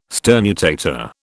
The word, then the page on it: sternutator: British English